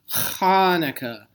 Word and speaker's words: Hanukkah: The best way to say it is you've got to, it's almost like bringing up some spit. It's Hanukkah. – Clear your throat.